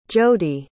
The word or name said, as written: jodee